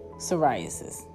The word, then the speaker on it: psoriasis: Autoimmune diseases, POS, which is polycystic ovary syndrome, skin conditions, psoriasis. You don't pronounce a P. I be forgetting. Psoriasis, ringworm.